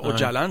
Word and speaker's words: Öcalan: Quand on le prononce à la turque, là, Öcalan, ouais, ça s'écrit O avec deux petits points dessus. – Ouais. – C-A-L-A-N. Même que O avec deux points, c'est comme un E. Donc, on va dire Abdullah Öcalan. Le C étant comme un J.